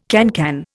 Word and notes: cancan: Indian

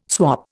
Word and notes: swop: Indian

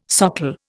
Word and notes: socle: Indian

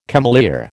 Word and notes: cameleer: American English

